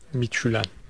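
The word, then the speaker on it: Midtjylland: Miçülen.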